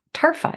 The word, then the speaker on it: Tarfa: I'm here today with Achara Tarfa.